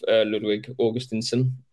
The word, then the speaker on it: Ludwig Augustinsson: Ludwig Augustinsson, trying this morning.